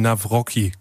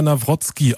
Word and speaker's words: Nawrocki: Äh, ich habe nämlich deinen Nachnamen gelernt gelesen und ich habe ihn Navrocki gelesen. Er wird aber Navrotski ausgesprochen.